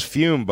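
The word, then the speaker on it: Füm: This is Fume, buddy. Pronounced F-U-M-E, but spelled F-U-M with umlauts, I think, like Motley Crue.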